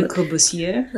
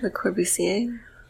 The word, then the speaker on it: Le Corbusier: Le Corbusier. – Le Corbusier.